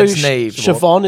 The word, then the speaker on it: Siobhan: Neve is a big Neve Kelly, like, that was one of the players, and her name is spelled N-I-A-M-H. – Yeah. – Niamh. – And also Siobhan is like Siobhan.